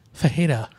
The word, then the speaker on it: Ferreira: Did I say the name wrong? Diego Ferreira? – Fajeda. – Oh, Fajeda, yeah.